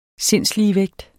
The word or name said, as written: sindsligevægt